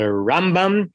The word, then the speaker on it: Rambam: But he would say the way you distinguish between them is that it's the Rambam and the Ramban, that the Ramban gets the stress on the second syllable and the Rambam gets the stress on the first syllable, so that you're not making a confusion.